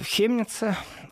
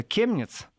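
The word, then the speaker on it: Chemnitz: И э, Хемница, в принципе, есть второе произношение названия города Хемниц, это Кемниц.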